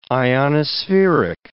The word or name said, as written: ionospheric